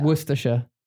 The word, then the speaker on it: Worcestershire: It doesn't really have a big difference. You just take the E away. Worcestershire. – Worcestershire. – Worcestershire. Worcestershire. So you pronounce C-E-S together, Worcestershire. – Worcestershire, yeah. – Okay. – And also the weird. S-H-I-R-E, which is, if you, in American English, if you say, would be Shire.